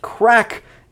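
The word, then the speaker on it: craic: Yes, that is pronounced crack, even though it's C-R-A-I-C. Because in Ireland, they don't just speak English, but a kind of language called Irish as well.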